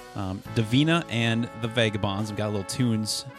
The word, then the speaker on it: Davina and the Vagabonds: Absolutely, Davina and the Vagabonds.